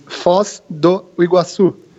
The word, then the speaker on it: Foz do Iguaçu: It's Foz do Iguaçu. – Foz do Iguaçu. – Yeah, Foz do Iguaçu.